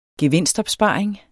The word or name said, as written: gevinstopsparing